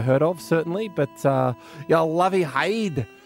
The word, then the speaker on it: heid: Head is hide. – H-E-I-D, they spell it. Haid. Yeah. – Haid.